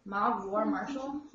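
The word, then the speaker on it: Mogg War Marshal: Mog War Marshal. – Did I pronounce that correctly? – Yeah, perfectly.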